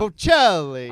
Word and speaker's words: Bocelli: Bocelli.